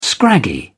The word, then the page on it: scraggy: British English